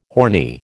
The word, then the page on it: horny: American English